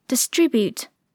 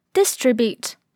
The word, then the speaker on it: distribute: 首先来看动词 distribute，它也可以读作 distribute，重音既可以在第一个音节上，也可以在第二个音节上。